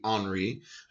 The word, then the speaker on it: Henri: Yeah, and it doesn't say it credits the event to the authority as Daniel Rops Henry, which is more of a French name the way it's spelled. I said Henry and it would be Henri,